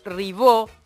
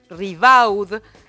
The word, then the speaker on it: Ribaud: Se trata de Alejandro Ribaud, es como se pronuncia en francés, Ribaud, se escribe en español literalmente, es uno de los principales involucrados en la causa por la reventa de entradas en River Plate.